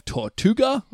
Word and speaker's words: Tortuga: Jade will be directed to go to the pirate city of Tortuga. I don't know if I'm pronouncing that. – Yeah, that's right.